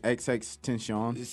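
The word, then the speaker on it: XXXTentacion: No, you know he's finally pronounced it. It's like extension. – It's like XX tension.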